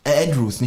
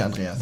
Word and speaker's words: Andrews: Ist Andreas aber nicht auch Englisch ausgesprochen? Andrews, nicht Andreas.